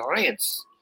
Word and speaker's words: Reims: They call it Rance for some reason. The way they pronounce it is Rance. So it sounds like an N, R-A, anyway.